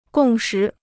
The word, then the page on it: 共识: gòng shí